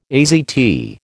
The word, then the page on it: AZT: American English